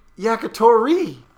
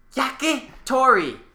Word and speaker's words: Yakitori: It's Yakitori. There's an accent over the I. – Yakitori. – No, over the middle I. Yakitori.